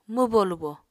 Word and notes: mwbo-lubo: Apatani

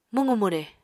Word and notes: mwqu-mwre: Apatani

